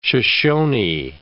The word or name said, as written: Shoshoni